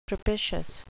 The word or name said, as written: propitious